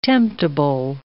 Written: temptable

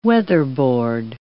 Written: weatherboard

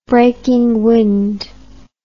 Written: breaking wind

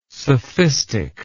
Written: sophistic

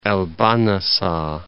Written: El Bahnasa

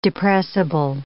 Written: depressible

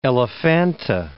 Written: Elephanta

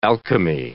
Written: alchemy

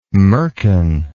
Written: merkin

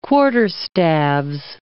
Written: quarterstaves

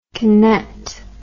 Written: kinet

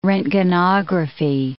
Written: roentgenography

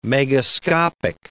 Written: megascopic

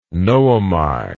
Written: noemi

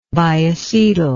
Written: biacetyl

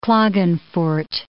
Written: Klagenfurt

